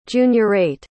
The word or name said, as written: juniorate